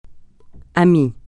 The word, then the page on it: ami: francais